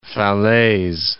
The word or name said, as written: Falaise